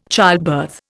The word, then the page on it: childbirth: Indian